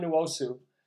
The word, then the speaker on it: Nwosu: I'm not going to butcher his name because I know how it's pronounced. It's Uchenna Nwosu. Nwosu from USC, he was great.